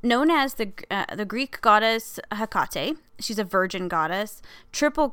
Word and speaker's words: Hecate: generally with Greek words of three syllables, the accent is in the middle, hence the correct pronunciation of her name is Hecate. And they have, like, the phonetic, you know, H-E-H, tack K-A-H, tack T-E-H. And they say that Hecate is also an acceptable pronunciation.